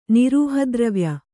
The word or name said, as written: ನಿರೂಹದ್ರವ್ಯ